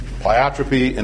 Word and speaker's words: pleiotropy: Both of these words are often mispronounced, pleiotropy and epistasis.